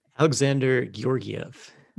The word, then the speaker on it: Alexander Georgiev: Alexander Georgiev.